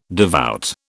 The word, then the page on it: devout: British English